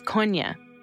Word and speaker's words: Kony: The proper pronunciation is Konya. – It's sort of like cognac, always telling about the advocacy movement that they never pronounced his name correctly.